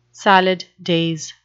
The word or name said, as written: salad days